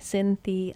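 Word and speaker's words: Cinthia: So if you just think of it phonetically, Cynthia Hyatt. That's how my name is spelled.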